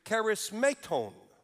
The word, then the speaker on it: charismaton: The word in verse 4 for gifts is spelled this way in the Koine Greek, charismaton. That's how you would say it.